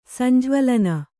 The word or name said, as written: ಸಂಜ್ವಳನ